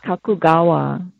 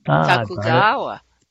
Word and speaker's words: Kakugawa: And she's introduced me to an amazing poet, which we connected, and she's going to be on the show too, Frances Kakuagwa. Did I say that wrong? – Kakugawa. – Kakugawa.